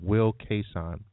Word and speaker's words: Wil Cason: And for all of our listeners, today we're actually talking to a very motivating, very educated, very articulate man, Wil Cason. Is that Cason? C-A-S-O-N? Am I pronouncing Cason. – That right, Will? – Cason. Yes, Wil Cason. Yeah. – Cason. Wil Cason.